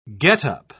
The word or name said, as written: get-up